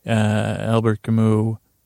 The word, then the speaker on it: Albert Camus: Albert Camus, 1942.